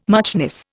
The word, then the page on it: muchness: Indian